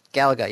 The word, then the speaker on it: Galaga: Galaga, yes.